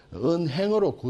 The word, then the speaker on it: 은행: 원래 가계란 뜻으로 읽힐 때도 한자의 원래는 한과 발음이 같은데 은항이 되어야 되는데 은행으로 굳어졌다.